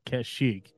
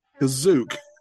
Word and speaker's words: Kashyyyk: Well, they definitely said that they arrived on Kashyyyk. The thing that always drove me nuts about Star Wars. – No, Kazook. – I heard both pronunciations are accepted.